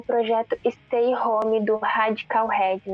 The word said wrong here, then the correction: Radiohead: Não sei se eu falei certo, gente, mas tá ok. – Radiohead. – Em uma atitude... É, Radiohead. Isso aí.